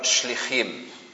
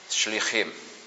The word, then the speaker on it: shlichim: Y la palabra en singular es shaliach, con acento en la i, y en plural shlichim, con el acento en la última i, shlichim, como aparece aquí.